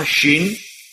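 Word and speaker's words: Shin (שׁין): En nou is het zo, als er hier aan de rechterkant een puntje boven staat, dan wordt die uitgesproken als SJ of SCH, Shin. En als die aan de linkerkant staat, dan is het een Sin. Dus zonder J erachter.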